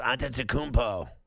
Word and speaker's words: Antetokounmpo: Yes, Antetokounmpo.